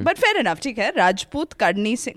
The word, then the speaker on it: Rajput: It's not Rajput. – It's Rajput.